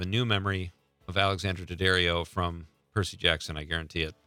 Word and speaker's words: Daddario: Alexandra Daddario.